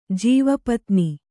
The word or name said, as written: ಜೀವಪತ್ನಿ